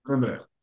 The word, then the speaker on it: Edinburgh: So it's, if you want to say it just like a Scot, you would say Edinburgh. – Edinburgh. All Edinburgh. Right. – Edinburgh. Almost like Edinburgh, just all of Edinburgh.